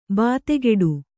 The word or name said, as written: ಬೆದೆಗೂಡು